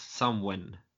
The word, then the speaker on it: Samhain: Eh, halloween kommer från den irländska traditionen med Samhain som uttalas Samwen något i den stilen.